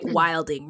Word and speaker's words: wildin': I don't know, like a term like wilding, right? It's spelled W-I-L-D-I-N-G, but Black people say wildin', right? You're wildin'.